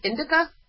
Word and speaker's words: indica: And he had told us, he pronounced it indica, like indigo.